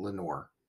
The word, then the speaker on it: Lenoir: It looks like Lenoir. He pronounced it Lenoir.